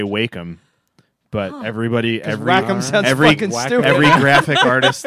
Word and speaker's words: Wacom: That's actually what we've started to do, because he broke out the Wacom tablet thing. – Yeah, Illustrator. – They like you to pronounce it Wacom.